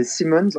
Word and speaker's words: Simons: Bref, c'est compliqué la vie sans Simons oui, je, si, tu, veux bien, je vais te corriger la, prononciation. Comme il y a deux M, c'est Simons en fait.